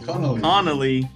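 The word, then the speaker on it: Connelly: There's Jennifer Colony. Is that how you say it? Colony. Connolly. – Connolly.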